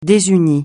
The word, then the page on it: désuni: francais